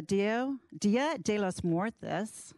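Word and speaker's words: Día de los Muertos: Todos Santos hosted a Dia de los Muertos.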